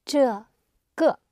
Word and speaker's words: 这个: 这的 shu, zhe, ge. It's ge. Then you say this book. – Zhe, ge, shu.